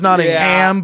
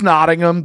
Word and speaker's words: Nottingham: I thought it was Nottingham, yeah, but I pretty quickly learned it's Nottingham. They don't like it when you say it wrong.